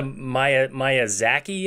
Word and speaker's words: Miyazaki: We haven't even gotten to Miyazaki yet.